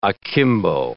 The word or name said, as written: akimbo